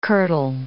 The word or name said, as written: kirtle